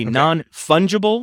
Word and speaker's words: non-fungible: A non-fungible, am I saying that right? – That's right. Yeah.